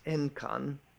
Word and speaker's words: Enkan: And the first time that he met this Enkan, to give him his Japanese pronunciation, the old master said to him, the Tathagata had a secret word, but Mahakashapa could not keep it hidden.